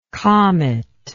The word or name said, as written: cometlike